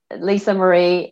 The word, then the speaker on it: Lisa Marree: Uh, Lisa Marree, M A R R double E dot com.